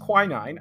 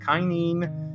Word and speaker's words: quinine: So again, it's pronounced quinine. I had to actually look that up, because a lot of times when you see it spelled, you're like, is that kinine?